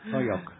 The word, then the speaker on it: Holyoke: Well, yes, but you're from New York, so you're giving it a New York pronunciation. – Oh, okay. – Holyoke. – Holyoke.